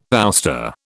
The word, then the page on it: ouster: British English